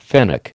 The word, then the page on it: fennec: American English